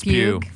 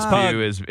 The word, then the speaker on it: Pugh: Florence Pugh. – Florence Pugh is Pug. – I think it's pronounced Pug.